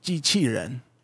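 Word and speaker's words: jiqiren (机器人): It's pronounced jiqiren. And it jiqiren means machine person.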